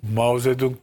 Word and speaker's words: Mao Zedong: Hasbelkader Mao Zedong döneminde, bak onu en iyi telaffuz edenlerden biriyimdir Türkiye'de. Herkes Çetong meçhuk der. Çinlilerden duymuştum. Çünkü Mao Zedong beyefendinin adı.